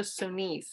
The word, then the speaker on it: Sonis: I'll spell that S-O-N-I-S. Sorry to my French teachers. Francis and I decided to pronounce it like that so it's easier for you people to understand how we're saying it, S-O-N-I-S.